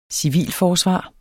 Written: civilforsvar

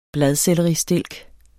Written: bladselleristilk

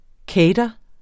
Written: cater

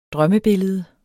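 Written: drømmebillede

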